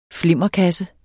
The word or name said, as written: flimmerkasse